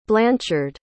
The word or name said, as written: blanchard